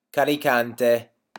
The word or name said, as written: carricante